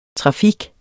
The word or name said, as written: trafik